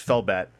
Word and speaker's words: Felbat: Phonetically, F is under the H column in the Japanese alphabet, so there's all kinds of layers to his name. Also, it's just like, yeah, it's not Hellbat, it's Felbat.